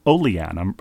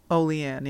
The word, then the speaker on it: Olean: Lastly, writes Tom, "having been to Olean," I'm pronouncing that correctly now? – Olean, yeah.